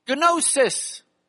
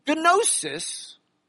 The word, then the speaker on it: gnosis: And the Greek word, the Greek root for knowledge is the Greek word gnosis. They pronounced the G. We have made it silent.